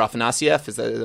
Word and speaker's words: Afanasieff: I'm sure I'm going to pronounce his name wrong, but Walter Afanasieff. Am I close? – I have pronounced it so many different ways, but I think that is right.